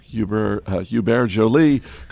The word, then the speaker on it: Hubert Joly: And Ron, CEO Hubert Joly called the Is that how you pronounce that? – Yeah, I go with the French. – It sounds festive. – Wow. Hubert Joly, isn't it?